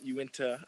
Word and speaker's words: Uinta: I've told you guys about Uinta. I believe I'm pronouncing it right. Uinta is the brewery. I think it's a part of the mountain range in Utah. – Yes, sir. Confirmed.